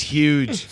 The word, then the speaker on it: huge: It's huge. – It's going to be huge. – It's very good. It's very good. – You pronounce the H. Huge.